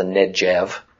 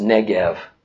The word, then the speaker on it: Negev: And by the way, please don't call this the Negev. Okay, there's no soft G. Sounds like a J in Hebrew. All the G sounds are hard G's, like in goat. Okay, so it's pronounced Negev.